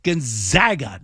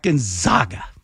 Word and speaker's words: Gonzaga: But they did that so people would pronunciate the name properly. It's Gonzaga, not Gonzaga.